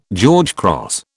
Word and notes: George Cross: British English

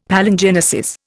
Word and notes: Palingenesis: Indian

